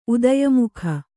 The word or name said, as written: ಉದಯಮುಖ